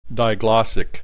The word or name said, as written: diglossic